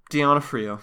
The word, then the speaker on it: D'Onofrio: How would you pronounce that last name? – D'Onofrio.